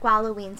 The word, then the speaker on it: Gwalloween: Yeah, the how do we pronounce it? Gwalloween. – DG called it Gwalloween, so I'm just going with Gwalloween.